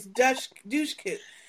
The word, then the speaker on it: Dushku: I gotta look up this douchey coup. – Okay. – Douchey? – It's not douchey, it's douche coup.